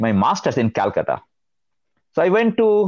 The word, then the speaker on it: Calcutta: I know, if I remember right, you were born in Calcutta.